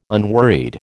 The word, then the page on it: unworried: American English